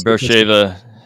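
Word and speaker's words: Beersheba: and they hit up against the Canaanites there in southern Palestine, um, um, uh, uh, uh, Is that the way you pronounce it now? I've always yeah, Bersheba,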